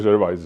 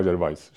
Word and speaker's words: Gervais: Ricky Gervais se čte Gervais, že jo, on si sám říká Gervais, že jo, jako Gervais, že to je tak jako, má tam tu zvláštní tu koncovku francouzskou, no.